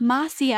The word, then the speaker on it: Macia: So because I had to be like, how do you pronounce it, because it's spelled M-A-C-I-A crystal. – Oh. – And I was like, kept saying it, and she's like, no, it's Marcia.